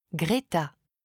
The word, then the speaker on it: Greta: Greta. – Comment ça s'écrit ? – G-R-E-T-A.